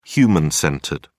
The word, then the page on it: human-centered: British English